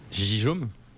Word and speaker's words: Gijom: Alors ça s'écrit G-I-J-O-M. – Gijome. – Oui, c'est exactement ce que me dit Léo. Ça se prononce Guillaume en espéranto.